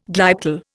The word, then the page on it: glyptal: Indian